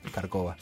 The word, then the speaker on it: Kárkova: Trabaja en un comedor en Villa Kárkova. Ahí se le cambia el acento en el barrio, no sé uh-huh. por qué. Kárkova.